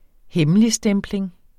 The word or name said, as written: hemmeligstempling